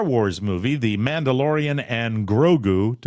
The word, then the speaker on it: Grogu: Grogu is Baby Yoda that you see. Yeah. Yeah, exactly. – Grogu. I am Grogu.